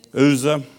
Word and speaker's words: Uzzah: In the seventh verse, and the angel of the Lord was kindled against Uzra. What's how you pronounce that? The Uzra? Huh? Anybody want to pronounce me on that? Uzzah.